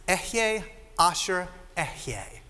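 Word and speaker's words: Ehyeh Asher Ehyeh: In Hebrew, this is how it would have been pronounced. Ehye Asher Ehye.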